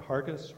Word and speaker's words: Hargis: In 1971, Ron Hargis, am I pronouncing that right? Hargis, with a hard G.